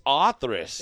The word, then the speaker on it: Othrys: I thought it was And honestly, I think we actually pronounce it wrong, to be honest. I'm going to say this on here. – Othrys. – I think it's Othrys. – It is. – I've looked up and tried to figure out is actually the pronunciation. – When you type it into the translator, it says Othrys.